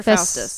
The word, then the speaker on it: Faustus: The book that Dr. Avchenko is reading is The Tragical History of the Life and Death of Dr. Festus. – Festus or Faustus? – Faustus. I can't pronounce things.